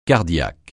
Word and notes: cardiaque: francais

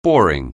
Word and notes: boring: anglais

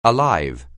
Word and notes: alive: English

